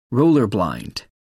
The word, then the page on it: roller blind: American English